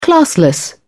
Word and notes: classless: British English

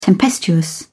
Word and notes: tempestuous: British English

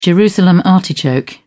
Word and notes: Jerusalem artichoke: British English

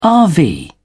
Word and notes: rv: British English

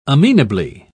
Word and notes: amenably: British English

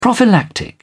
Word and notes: prophylactic: British English

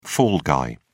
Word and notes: fall guy: British English

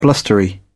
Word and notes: blustery: British English

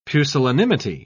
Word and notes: pusillanimity: British English

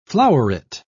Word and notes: floweret: British English

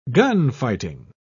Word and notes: gunfighting: British English

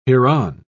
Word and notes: hereon: British English

